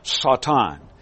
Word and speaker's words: Satan: His Hebrew pronunciation is Satan.